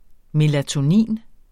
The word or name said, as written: melatonin